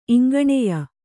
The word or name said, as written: ಇಂಗಣೆಯ